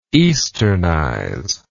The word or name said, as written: easternization